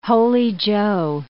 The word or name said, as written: Holy Joe